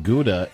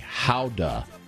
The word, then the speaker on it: Gouda: Boys, I learned recently that Gouda is pronounced like Howda, says T-Mod. That is not true. – Yeah, we're not doing that.